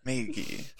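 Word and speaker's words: Maggy: The small fire called her Maggie. Oh, like Magi? Okay. Yeah, is that how you pronounce it? Is what Cersei says? – Maggie. – Oh, is that how you pronounce it? Okay, I see.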